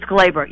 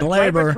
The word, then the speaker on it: Gleyber: It's Gleyber, yes. – Gleyber Torres.